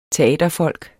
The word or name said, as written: teaterfolk